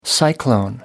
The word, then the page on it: cyclone: American English